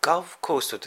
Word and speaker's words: Gulf Coast: で、これ発音が面白くて、ガルフコーストってね、日本人的にはゴルフコースっていうふうに聞こえるんですよね。これで、なんだろう。石川遼がどうしたのかとかね、とか思ってしまいますが、ガルフコーストなんですね。